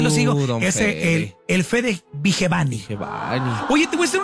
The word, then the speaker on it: Vigevani: Porque nadie sabía pronunciar su apellido. Entonces ya sabemos que es Fede Vigevani.